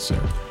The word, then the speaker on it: SiR: It's Sir. That's how you pronounce it? – Yeah, Sir.